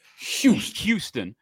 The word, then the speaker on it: Houston: My son, if Houston, did I say it right there? – Houston.